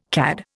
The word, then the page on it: CAD: Indian